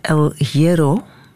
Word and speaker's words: El Hierro: Daar wil ik ook wel eens zitten, want die staat op het Canarisch eiland El Hierro.